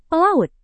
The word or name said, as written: pelaut